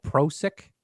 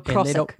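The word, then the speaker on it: Prošek: Did you see where Prosecco is suing Croatia because they have a wine called Prosek? – Yeah. – Is that how you pronounce it? – Yeah. Or Prosek.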